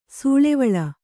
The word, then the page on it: ಸೂಳೆವಳ್ಳ: sūḷevaḷḷa